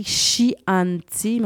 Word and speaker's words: Chianti: Donc, ça s'écrit Chianti, mais on dit Chianti, qui est une région qui offre un splendide paysage parsemé de vignobles, de forêts de châtaigniers.